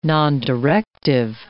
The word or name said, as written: nondirective